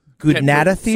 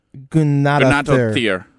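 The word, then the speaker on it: Guðnadóttir: I was gonna say, the TT is sometimes pronounced with a th, so it's like a Gunnadathir.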